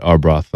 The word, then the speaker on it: Arbroath: How do you say Arbroath? – Arbroath. Yeah, Arbroath.